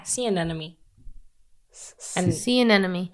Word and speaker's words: sea anemone: Is that how you say it? – Yeah, sea anemone.